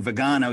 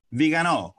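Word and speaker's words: Viganò: I keep saying Vigano. You said it's, how do you say it? – Vigano.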